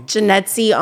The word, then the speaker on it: Janetzy: Janetzy on air. And my name is spelled J-A-N-E-T-Z-Y.